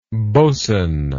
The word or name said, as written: bosn